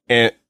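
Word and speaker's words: Anohni: Like Anhony.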